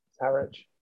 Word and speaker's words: Harwich: Uh, Jurassic Pro Wrestling, which of course is based in Harwich, Essex, have announced Harwich. – Harwich. I thought it's Harwich. Is it Harwich? – Harwich. – Harwich. Uh, my pronunciation terrible.